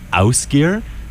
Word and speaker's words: Ásgeir: That was King and Cross by, just figured out how to pronounce his name, Ásgeir from Iceland.